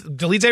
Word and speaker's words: Dolidze: Am I getting that last Dolidze right? – Yeah, you're nailing that.